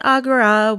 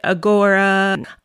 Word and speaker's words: Agora: You may notice that I pronounce it Agora, where a lot of people in the film pronounce it Agora.